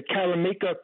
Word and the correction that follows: Maricopa: County, is that? – No, Maricopa County, yeah.